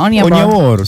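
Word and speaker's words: Onyeabor: Oniabor.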